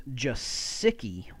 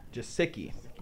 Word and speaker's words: Gesicki: The official pronunciation is Gesicki. – Gesicki.